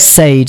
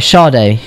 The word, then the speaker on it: Sade: It's Sade. – Sade. – Oh, is that how you say it? – Yeah. – Mm-hmm. Right, Sade.